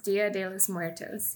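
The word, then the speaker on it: Día de los Muertos: Dios de los Muertos? – That's really close. Dia de los Muertos.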